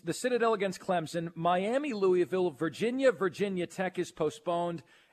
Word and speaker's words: Louisville: See, you're messing the last part. It's Vol. – Vol. Yeah. Louisville. – No, Louisville. – Louisville. – There you go. You got it on the last one.